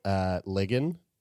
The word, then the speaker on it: Ligon: Zoe Ligon. Rhymes with friggin'.